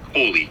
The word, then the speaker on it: Kolle: His name is Ricky Valerie Coley. – Coley? – I'm pronouncing it. Cooley, K-O-L-L-E. – Okay.